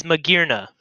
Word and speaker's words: Magearna: The whole gimmick of her name is that she is made out of gears. It is Magearna. Say it right.